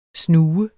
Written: snue

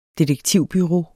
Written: DETEKTIVBUREAU

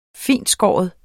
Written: fintskåren

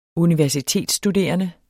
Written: universitetsstuderende

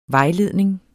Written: vejledning

